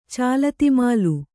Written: ಚಾಲತಿಮಾಲು

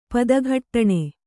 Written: ಪದಘಟ್ಟಣೆ

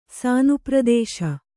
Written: ಸಾನುಪ್ರದೇಶ

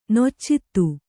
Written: ನಿಚ್ಚೈದೆ

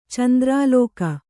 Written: ಚಂದ್ರಲೇಖೆ

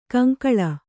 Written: ಕಂಕಳ

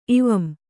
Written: ಇವಂ

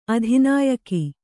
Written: ಅಧಿನಾಯಕ

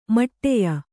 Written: ಮೌಢ್ಯ

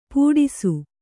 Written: ಪುಟಿಸು

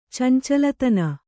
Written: ಚಂಚಲತನ

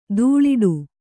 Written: ಧೂಳಾಟ

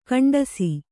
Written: ಗೆಂಟಿಸು

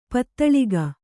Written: ಪದುಳಿಕೆ

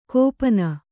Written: ಗೋಪನ

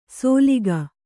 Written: ಸೋಲಿಗ

